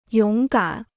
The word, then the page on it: 勇敢: yǒng gǎn